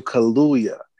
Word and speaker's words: Kaluuya: I'm coming to this practice and starting to call people bruv now because, you know, I'm trying to be on my Daniel Kaluuya because it just seems like he could do anything. And I hope I'm not saying his name wrong. It's Daniel Kaluuya.